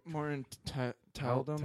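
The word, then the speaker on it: Morten Tyldum: Morton Tildum.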